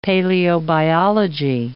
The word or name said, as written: paleobiology